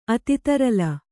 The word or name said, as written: ಅತಿತರಲ